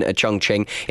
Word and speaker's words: Chongqing: Keeping me sane on my journey to school here in Chongqing in China.